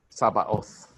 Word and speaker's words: Sabaoth: So in this case, it just happens that Jehovah, the way it's pronounced is Sabaoth. It sounds like Sabbath, right? Sabbath is different from Sabaoth. That's how it's pronounced.